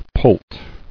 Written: Poult

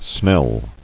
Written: Snell

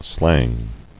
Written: Slangy

